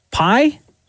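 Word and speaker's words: Pai: It's P-A-I. pie.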